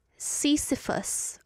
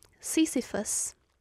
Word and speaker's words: Sisyphus: Sisyphus. Oh, I was right. Sisyphus.